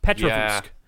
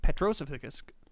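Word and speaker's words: Petrozavodsk: Wow, I butchered that. Petrovsk. Yeah. Petrozavusk.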